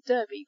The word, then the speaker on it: Derby: which also confuses people because it's spelled D-E-R-B-Y. They think, oh, Derby, but it's not, it's Derby in Derbyshire. Okay, number three is this group of letters C-E-S-T-E-R.